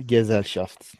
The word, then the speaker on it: Gesellschaft: Gemeinschaft ve Gesellschaft galiba böyle okunuyor Almanca'da. – Gemeinschaft and Gesellschaft. Doğru.